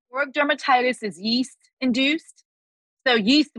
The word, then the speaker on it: seborrheic dermatitis: Seborrheic dermatitis. How do you say that? – Seborrheic dermatitis. – Seborrheic dermatitis. Yes. As an adult.